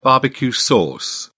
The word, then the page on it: barbecue sauce: British English